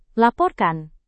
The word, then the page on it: laporkan: indonesian